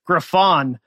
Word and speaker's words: Griffon: Right out of the gate, I was like, oh, and I appreciate you didn't correct how I said Griffin. You said it right when you said Griffon.